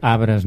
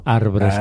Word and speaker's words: arbres: I a part pronuncia bé, diu arbres, no arbres, com diuen ara tothom, i que és la R aquesta és muda. – És una altra correcció absurda, perquè efectivament és mudíssima. – Arbres rima amb cabres.